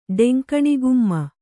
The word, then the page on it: ಡೆಂಕಣಿಗುಮ್ಮ: Kannada